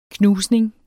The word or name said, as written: knusning